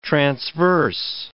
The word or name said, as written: transverse